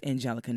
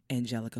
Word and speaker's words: Angelica: Once again, this is your girl Angelica Mackey. My handles for Twitter is at PK Mackey, Instagram at PK Mackey, and Facebook Angelica Mackey. And that's Angelica, not Angela, Angelique. Angelica, four syllables.